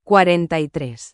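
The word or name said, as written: cuarenta y tres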